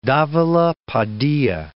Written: Davila Padilla

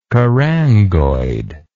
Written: carangoid

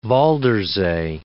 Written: Waldersee

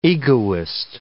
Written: egoist

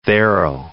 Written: there'll

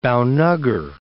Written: Bhaunagar

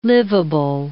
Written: livable

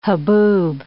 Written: haboob

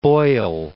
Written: Boyle

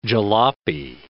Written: jalopy

